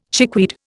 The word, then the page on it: chickweed: Indian